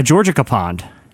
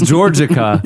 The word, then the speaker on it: Georgica: It's Georgica. – It could pond. – Be the name George, and then like a. – It's Georgica. But Jesse pronounced it Georgia Capon.